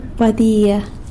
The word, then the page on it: badia: sanlucasquiavini